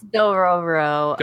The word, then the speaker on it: Dororo: Sounds like you want to talk about Dororo. – Dororo? – Dororo. So how about you talk about Dororo? – Is it Dororo, or is it... – It's Dororo.